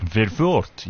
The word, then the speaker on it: Verwoerd: So her name is Marika Verwoerd. I'm just pronouncing it with an Afrikaans accent. It's probably Verwoerd, yeah.